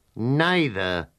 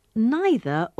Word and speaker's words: neither: Neither have I. – Professor, which is correct? Neither or neither? – Both are correct. English is flexible. Some British people and most North Americans say neither.